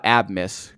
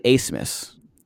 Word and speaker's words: Abmas: I thought Abmas because it's A-B-M-A-S. It's actually Asmus. And I don't know how A-B-M-A-S became Asmus.